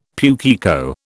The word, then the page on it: pukeko: British English